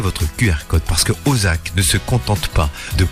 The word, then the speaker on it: O2ZAK: Voici Ozak, l'application en fait qui révolutionne l'achat de places de cinéma. L'appli Ozak, alors ça s'écrit O2ZAK, nous permet de réserver nos séances donc du ciné avec des réductions allant jusqu'à 50%.